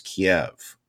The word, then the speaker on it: Kyiv: I cannot pronounce it as Kyiv. To me, it's Kiev. But it's Kyiv.